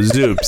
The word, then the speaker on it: Zuup: Shoop, shoop. – Oh, shoop. No, Zoop. Z-U-U-P.